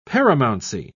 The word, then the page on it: paramountcy: British English